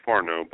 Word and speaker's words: Farnow: It's actually Farnow, but close. I get it all the Farnow? – Time. – Okay. F-A-R-N-O-W.